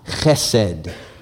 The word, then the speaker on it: chesed: It is the word chesed. Why don't you say that with me? Chesed, okay? You gotta get it in your throat. If you're not spitting, you're not saying it right.